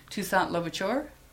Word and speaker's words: Toussaint Louverture: Toussaint Louverture.